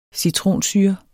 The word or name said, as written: citronsyre